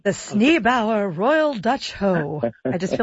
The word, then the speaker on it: Sneeboer: Sneebauer. So it's spelled S-N-E-E-B-O-E-R, Sneebauer, the Royal Dutch Hoe.